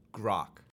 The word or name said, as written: grok